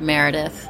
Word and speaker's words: Meredith: You can find us on Instagram at Jules, and Jules' name is spelled J-U-L-E-Z. Then it's _ and Meredith. And my name is spelled M-E-R-E-D-I-T-H.